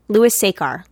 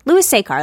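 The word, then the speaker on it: Louis Sachar: I don't know if we're saying his name right. Louis Sekar? – I always heard Sekar? Louis Sekar. Louis Sekar. That's what I've always heard.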